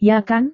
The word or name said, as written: ya-kan